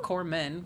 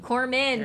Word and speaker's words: Corman: Corman. – Corman.